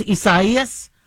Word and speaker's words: Isaias: It's actually pronounced Isaias. And so many media outlets, including myself, have had to correct yourself. I know the Latino pronunciation is Isaias, but we've been calling it Isaias when that name was used in the past. I do appreciate the appropriate pronunciation.